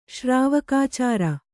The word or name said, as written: ಶ್ರಾವಕಾಚಾರ